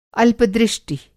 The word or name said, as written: अल्पदृष्टि